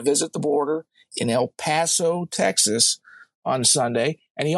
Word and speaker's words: El Paso: Yeah, it's pronounced El Paso. Thanks. Yeah. – I said El Paso.